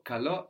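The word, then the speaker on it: kalo (καλώ): So kalo as a verb means I invite or I call. And we have the accent on the end, no?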